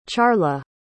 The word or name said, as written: charla